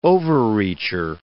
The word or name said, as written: overreacher